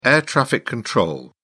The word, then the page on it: air traffic control: British English